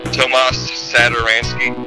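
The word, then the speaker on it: Tomáš Satoranský: The one change is Tomas Zadaransky is taking over for Chris Dunn at starting point guard. – How do you say his name? – Tomas Satoransky.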